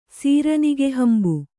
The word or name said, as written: ಸೀರನಿಗೆಹಂಬು